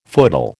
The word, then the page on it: footle: American English